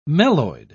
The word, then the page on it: meloid: British English